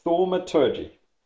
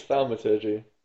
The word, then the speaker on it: Thaumaturgy: Thaumaturgy. – I think it's Thaumaturgy, but not bad.